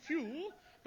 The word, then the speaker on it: Pugh: N-E-D-D-I-E? Neddy? What was next? – Neddy Pugh Seacoot. – Pugh? P-H-E-W? – No. It's pronounced Pugh, but it's spelt Pug. – Pug, yes. P-U-G-H.